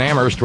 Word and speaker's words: Amherst: Thank you to Joseph, and thank you to Terry in Amherst, where only the H is silent.